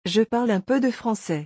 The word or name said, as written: je parle un peu de francais